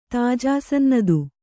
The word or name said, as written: ತಾಜಾಸನ್ನದು